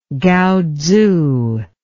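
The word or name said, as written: kaatsu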